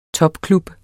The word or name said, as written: topklub